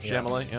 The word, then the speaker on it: Chalamet: and Timothy Chalamet. Did I say that right? Chalamet? – Chalamet, yeah.